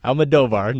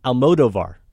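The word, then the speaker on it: Almodóvar: No, Almodovar. Nicely done, though, Maddie. I've heard a lot of people call him Almodovar. I've always said Almodovar.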